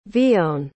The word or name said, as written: vione